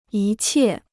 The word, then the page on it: 一切: yī qiè